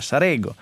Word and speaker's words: Sarego: E certo, in realtà tutti adesso a intervistare per esempio il primo sindaco del Movimento 5 Stelle a Sarego, vedete che ho imparato bene la pronuncia, Sarego,